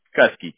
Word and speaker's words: Kuskie: Kuski, K-U-S-K-I-E. – K-U-S-K-I-E. Kuski is how you pronounce it? – Mm-hmm. – Okay. – Yep. Like Kuski.